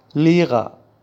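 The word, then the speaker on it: Lehrer: لغت بعدی که به معنای معلم هست لیغا تلفظ میشه.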